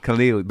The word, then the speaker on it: Khalili: Khalili.